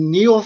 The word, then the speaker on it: Niall: And the story that the Chinese were exporting the flu around the world came from a guy named Neil Ferguson. It's spelled N-I-A-L-L.